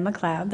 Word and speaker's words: McLeod: So my name is Deanna McLeod. That's D-E-A-N-N-A, McLeod, M-C-L-E-O-D.